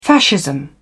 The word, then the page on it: fascism: British English